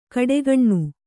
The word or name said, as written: ಘಟಾಗ್ನಿ